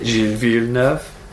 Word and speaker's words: Gilles Villeneuve: Time to look ahead to the circuit Gilles Villeneuve, which is how you have to pronounce the circuit Gilles Villeneuve.